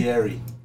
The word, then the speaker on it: Cagliari: Caligari versus Parma. And the winner is who? – In the Serie A. Caligari. – Caligari? – Cagliari. Cagliari. My bad, I said it wrong.